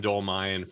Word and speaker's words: Dolmayan: John Dolmayan from System of a Down, and I should have asked you before, I'm pronouncing it right, right? – You got it.